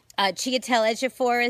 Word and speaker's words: Chiwetel Ejiofor: Chiwetel Ejiofor is in it, and I pronounced his name right.